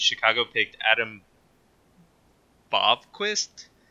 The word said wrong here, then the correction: Boqvist: I can't pronounce that. Also a defenseman from Sweden. – Looks like Boqvist. Looks like Boqvist.